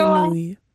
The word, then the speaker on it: grenouille: grenouille.